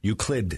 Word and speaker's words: Euclid: Euclid. It's a D.